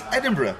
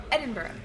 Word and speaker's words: Edinburgh: Edinburgh. It's close enough. – No, okay, how? Tell me. – It's Edinburgh. – Edinburgh. Oh, with the emphasis. – Brother. Edinburgh.